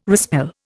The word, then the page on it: respell: Indian